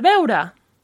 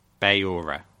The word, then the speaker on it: beure: para beure. – Para. No, I butchered that. Say it again. – Para beure. – Beura.